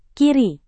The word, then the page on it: kiri: Indonesian